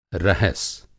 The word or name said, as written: रहस